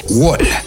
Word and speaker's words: Wohl: El proyecto se llama Wall. Se escribe W-O-H-L. Wall.